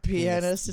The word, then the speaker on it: pianist: Yeah, pianist. It's like some people mispronounce it to a way where it sounds raunchy. It's like, why are you intentionally mispronouncing pianist?